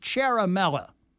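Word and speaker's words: Ciaramella: Bobby, the Italian name you keep slaughtering is pronounced Cherimella.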